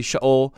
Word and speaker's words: Sheol: Or I tend to say Sheol when I'm not really thinking about it, but it's actually Sheol. It's a very fast sound going into the O, which is a long O sound.